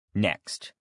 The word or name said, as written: next